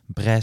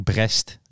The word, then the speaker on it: Brest: Mm Bre zeg je denk ik. Brest is het geschreven.